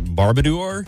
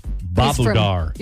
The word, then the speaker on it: Babudar: Barbadour? – Not bar. There's no R in it. It's. – Oh, there is no R. I'm sorry. – It's Babudar.